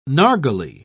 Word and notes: narghile: British English

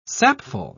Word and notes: sapful: British English

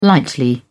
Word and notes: lightly: British English

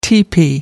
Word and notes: teepee: American English

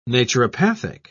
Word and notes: naturopathic: British English

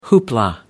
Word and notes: hoopla: American English

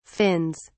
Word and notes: fins: British English

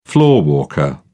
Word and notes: floorwalker: British English

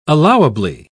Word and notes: allowably: British English

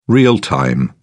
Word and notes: real-time: British English

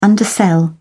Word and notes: undersell: British English

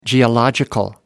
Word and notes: geological: American English

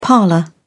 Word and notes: parlour: British English

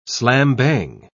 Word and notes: slam-bang: British English

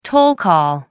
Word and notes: toll call: American English

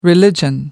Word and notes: religion: American English

